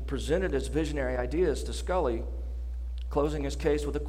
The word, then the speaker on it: Sculley: He approached John Sculley, which rhymes with Tully.